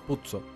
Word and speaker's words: Puzo: Puzo. – Puzo. Se escribe como pizza.